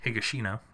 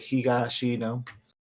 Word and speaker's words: Higashino: Somebody told me a long time ago that Japanese is very syllabic, so you typically have a consonant vowel, and that's one syllable. So if you look at a word, it'd be like Higashino.